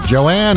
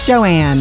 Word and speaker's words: Joanne: Now, it's spelled J-O-A-N-N-E. So, producer David and I were having this. Is it Joanne? Is it Joan? What is it? – It's Joanne.